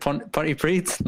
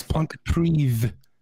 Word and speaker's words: Pontypridd: Is it Pontypridd? No. – Nearly. The two D's at the end is Pontypridd. It's a TH.